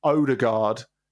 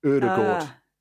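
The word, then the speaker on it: Ødegaard: So it's not Odegaard, it's Odegaard. Think the umlauted O at the beginning is very, very important.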